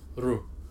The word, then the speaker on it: Ryu: It's Ryu, not Ryu. – No, I keep going back. – Hello, it's Ryu. Actually, the actual Japanese pronounce is Ru.